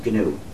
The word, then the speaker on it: GNU: So, when it's the name of our operating system, please pronounce a hard G. Pronounce it GNU.